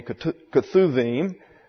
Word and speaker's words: Ketuvim: Then number 3, the writings, or the Ketuvim. This, by the way, the B is pronounced as a V.